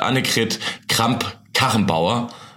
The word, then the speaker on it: Annegret Kramp-Karrenbauer: Interessanterweise hat Lars Klingbeil von der SPD auch im Interview gesagt, das liegt an Bayern und Annegret Kramp-Karrenbauer.